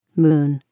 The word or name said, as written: moon